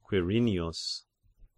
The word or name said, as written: Quirinius